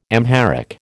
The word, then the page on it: Amharic: American English